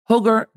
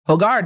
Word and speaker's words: Hogar: Hogar de HGTV, and others. Hogar? I pronounced that wrong. Hogar de HGTV and others.